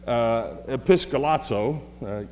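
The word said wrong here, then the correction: Episkiazo (ἐπισκιάζω): Episkiazo, see, I did say it wrong.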